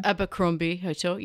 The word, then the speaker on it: Abercrombie: Correct pronunciation? – Abercrombie Hotel. Yes, you got that right.